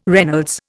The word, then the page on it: Reynolds: Indian